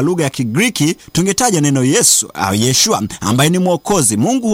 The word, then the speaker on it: Yesu: Kwa lugha ya Kigiriki hili jina linabadilika na kuwa Yeshua, ambalo baadaye linatakiwa kuwa Yesu, yani kwa Kiswahili linatamkwa kama Yesu, lakini Yesu ambaye ni mwokozi.